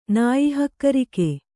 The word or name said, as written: ನಾಯಿಹಕ್ಕರಿಕೆ